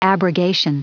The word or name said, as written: abrogation